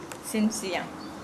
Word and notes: sinsia: Nukuoro

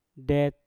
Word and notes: det: Mpur

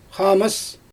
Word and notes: xaamɯs: Xyzyl

